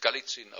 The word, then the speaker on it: Golizin: dem Fürsten Galizin, Golizin geschrieben, aber in Russland spricht man es Galizin aus, gekommen.